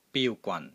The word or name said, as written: BIU1 GWAN3